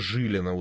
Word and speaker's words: Жилина: Надо Жилина, ударение на первый слог.